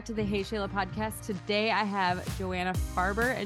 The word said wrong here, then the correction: Faber: and Julie King. I said that right, correct? – Faber.